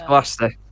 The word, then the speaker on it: Gloucester: Because they always do Gloucestershire or Gloucestershire. – Or Gloucester, or Gloucester, whatever. Gloucester. Or whatever. Yeah. It's Gloucester. Occupations.